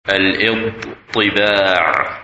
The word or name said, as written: Idhteba